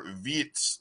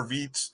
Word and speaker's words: Viets: Just trying to look and see how many innings Hunter Vietz, I think I'm saying that right, V-I-E-T-S, Hunter Vietz threw an 8-inning shutout, struck out 11 batters in that, only walked two, gave up 5 hits in that contest.